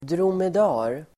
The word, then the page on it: dromedar: dromed'a:r